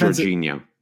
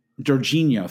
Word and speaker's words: Jorginho: I think it's Jorginho. – Jorginho, thank you.